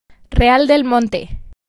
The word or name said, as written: Real del Monte